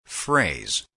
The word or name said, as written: phrase